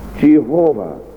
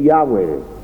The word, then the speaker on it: Yahweh: But you'll know that wherever you see the Lord in capital letters in the Authorized Version, the Hebrew spells out the word Jehovah. Or, as it has been thought more fitting in recent years, to pronounce the name Yahweh.